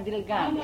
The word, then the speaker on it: Ταλγκάν: Ταλγκάν είναι με την προφορά τυλγκάν.